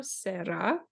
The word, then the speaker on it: Serra: I know it's pronounced Serra, but I can't do that rolled R really quickly within the word. So I'm going to say Serra, but there's two R's there, just so you know.